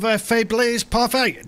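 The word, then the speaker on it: Faiblesse Parfaite: They go by the name of Faiblesse Parfait. Did I pronounce that correctly, guys? – That was pretty close, actually. Well done.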